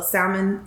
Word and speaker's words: salmon: So anything that goes in the air fryer, like give me a good ready-made meal, like the little salmon, the stuffed salmon from H-E-B, put that in the air fryer, pop some edamame. Huh? – You pronounce the L. – Salmon? I don't like saying salmon.